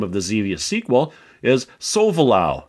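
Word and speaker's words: Solvalou: And all this time, I've been mispronouncing the name of your spaceship in Xevious. I keep saying Sovalu.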